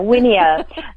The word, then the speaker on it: Wiener: And to clarify, my unique last name is Wiener. There's a little Dutch descent in there. Wiener. The Y is pronounced like an I.